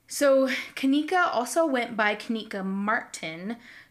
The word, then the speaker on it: Kenneka: Um, and I've only ever heard her her name pronounced Kenneka. So it's K E N N E K A.